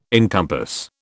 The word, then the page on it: encompass: British English